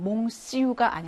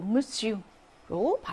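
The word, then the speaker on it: monsieur: 5번은 무스유라고 해서 이건 ON 할때 항상 발음으로 문제로 많이 나오는 건데 이거는 몽스유가 아니고 무스유로 발음이 된다라는 거죠.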